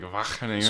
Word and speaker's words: Wageningen: So when I went abroad, and that was a lot of fun, I went to Wageningen. Or however the G's they pronounce in Dutch are a little strange. It's like a kind of thing. So it's like Wageningen.